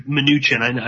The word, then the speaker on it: Mnuchin: Mnuchin, I just realized that's how you say his name.